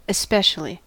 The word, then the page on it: especially: [ɛs.ˈpɛ.ʃə.li]; American English